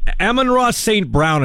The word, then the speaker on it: Amon-Ra St. Brown: Yeah, Amaron, uh uh, uh, uh, uh, sorry, Amon, or Amon-Ra St. Brown is how you say it.